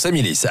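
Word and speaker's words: Amillis: On ne prononce pas le S, hein, j'ai bien compris, c'est ça, c'est Amilis. Ah, on le prononce, Amilis.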